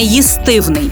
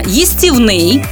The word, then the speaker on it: їстівний: Відповідаю, форма їстівний наголос саме на третьому складі стала більш поширеною і правильною через фонетичні зміни в українській мові. Раніше вживалася форма їстивний, але згодом звук і змінився на і.